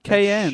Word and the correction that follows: Cheyenne: It's Cheyenne.